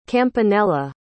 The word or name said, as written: campanella